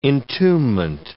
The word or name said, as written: entombment